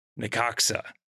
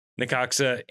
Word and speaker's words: Necaxa: That takes us over to South 4, where we have FC Dallas, Charlotte FC, and Necaxa, which is how you pronounce this. I feel pretty confident in saying. – I think so. I don't know. I haven't looked it up. – N-E-C-A-X-A. Necaxa is how I am choosing to pronounce this.